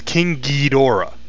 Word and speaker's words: Ghidorah: King Ghidorah is probably one of the most egregious, because Toho really, really wants, and some people really, really like, this pronunciation as King Ghidorah, with the hard G and Dora, like Dora the Explorer, at the end of it.